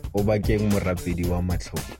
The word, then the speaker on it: Obakeng Morapedi wa Matlogo: Obakeng Morapedi wa Matlogo. – Morapedi. Yeah, Morapedi. I read that L like an L.